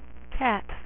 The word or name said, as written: Catwoman